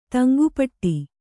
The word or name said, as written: ತಂಗುಪಟ್ಟಿ